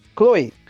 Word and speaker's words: Chloé: Bom, o que eu ia dizer para impecável nesse filme, eu vou dizer que é a moça que fez ele, a, é, é Chloé, como é que como é que fala isso? Chloe.